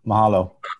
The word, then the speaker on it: Mahalo: Malaho. Mahalo. I got to say it right.